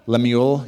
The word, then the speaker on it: Lemuel: Lemuel, yeah.